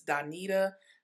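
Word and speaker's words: Doneta: If you go to any digital platform and just plug in my name, it's Donita Dawson. It's spelled with an E, not an I. So it's D-O-N-E-T-A Dawson, D-A-W-S-O-N.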